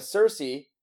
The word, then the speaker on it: Cersei: I mean, with Cersei, that's the hot queen, right? – Yep.